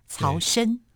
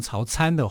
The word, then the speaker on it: 曹参: 我们先说明一下，曹参，曹呢是曹操的曹，参呢就是参加的参，只是在古音念我们要念成参，哦，像人参的参的音，只是他写字要写成参加的参，曹参。 – 如果把它念成曹参的话，哈，不是不可以啦，但是是一个错读啊，应该读成为曹参哈，才是一个比较正确的读音呐啊，那。